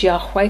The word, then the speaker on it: Yahweh: You know, don't you, that YHWH is the tetragrammaton, so called in the Hebrew, uh, which can be pr- pronounced as Yahweh because we have to put vowel sounds in, and has got transliterated as Jehovah, which means the I am.